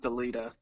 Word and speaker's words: Dalida: And that also was by Joel Dalida at J-D-A-L-I-D-A. – I believe it's pronounced Dalida.